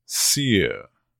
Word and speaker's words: sere: This is S-E-R-E, pronounced sear.